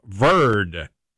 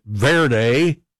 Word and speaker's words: Verde: And one of the best stories so far is from the underdog nation of Cape Verde. I think I've been pronouncing it Verde. My understanding now, it is pronounced Verde.